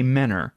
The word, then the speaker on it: Mentor: I think it was Menor, which is spelled mentor, but we say Menor because we're lazy.